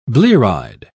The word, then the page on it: blear-eyed: British English